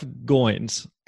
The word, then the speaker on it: Goins: Jeff Goins, he told me years ago, it rhymes with coins, and that's always just stuck with me.